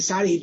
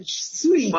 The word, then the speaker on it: Sudi: I, don't know why I want to say Saudi, but Sudi.